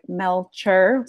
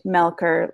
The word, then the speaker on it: Melcher: look me up, it sounds like Melcher, C H, but it's pronounced Melker, like a K.